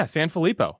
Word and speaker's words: Sanfilippo: Yeah, Sanfilippo.